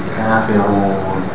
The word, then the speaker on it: كَافِرُونَ: See, the Ra has a dhamma. So how do we pronounce it? Kafirun.